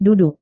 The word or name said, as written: duduk